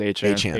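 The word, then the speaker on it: Achane: What's Miami's running back's pronunciation again? Achain? – Devin... I thought it was Achan. – Achan. – I think it is Achan.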